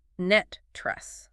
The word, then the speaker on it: NTREIS: It said pronounced net tress, like the word net, followed by the word tress.